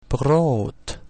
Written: prout

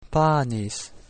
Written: panis

